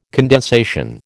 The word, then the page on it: condensation: American English